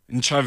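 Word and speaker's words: Ntshavheni: Minister of Presidency is a Minister Machinveni. Did I pronounce it right? – Nchaveni.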